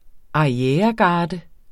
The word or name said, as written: ARRIEREGARDER